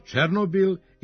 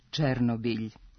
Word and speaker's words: Černobilj: Prema pravopisu kaže se Černobilj. Oblik Černobil najverovatnije je nastao po uzoru na izgovor imena ovog ukrajinskog grada u zapadnoevropskim jezicima ili previđanjem mekog znaka iza L u ruskom, preko kojeg je ovo ime došlo u naš jezik.